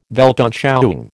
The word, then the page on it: Weltanschauung: American English